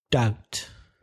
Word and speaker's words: doubt: Doubt. D-O-U-B-T, silent B. Don't confuse that with deuda, which is debt, D-E-B-T.